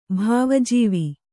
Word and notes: ಭಾವಜೀವಿ: Kannada

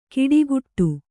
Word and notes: ಕಿಡಿಗುಟ್ಟು: kiḍiguṭṭu

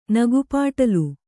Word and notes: ನಗೆಪಾಟಲು: kannada

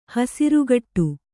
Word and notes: ಹಸುರುಗಟ್ಟು: hasurugaṭṭu

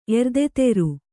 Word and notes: ಎರ್ದೆತೆರು: erdeteru

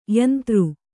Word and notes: ಯಂತೃ: yantř

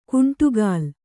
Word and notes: ಗುಂಡಕಲ್ಲು: kannada

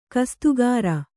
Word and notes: ಕಸ್ತುಗಾರ: kastugāra

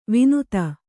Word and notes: ವಿನುತ: vinuta

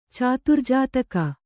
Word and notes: ಚತುರ್ಜಾತಕ: caturjātaka

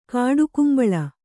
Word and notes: ಕಾಡುಗುಂಬಳ: kāḍugumbaḷa

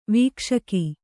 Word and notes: ವೀಕ್ಷಕ: vīkṣaka

